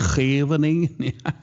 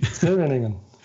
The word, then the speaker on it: Scheveningen: Äh, Den Haag und dieses wunderbare Seebad, was ein Deutscher, kein Deutscher richtig aussprechen kann, dieses Scheveningen. Scheveningen. Ja, oh gut.